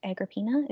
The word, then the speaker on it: Agrippina: Okay, so I went into this being like, I think it's pronounced Agrippina. Is that true? – Yeah.